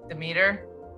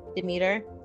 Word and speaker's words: Demeter: Am I saying her name right? Demeter. Demeter. – Yeah.